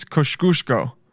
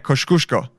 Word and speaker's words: Kosciuszko: I asked this Polish guy today, how to pronounce Kosciuszko. It's a street in New York, and it's I think it's Kosciuszko. Yeah, Kosciuszko.